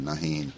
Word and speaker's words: Nahin: I hope I'm pronouncing Paul's name right. N-A-H-I-N, Nahin. Nahin.